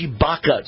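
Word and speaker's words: Tshibaka: So I want to welcome Kelly, it's pronounced Shibaka, to the show.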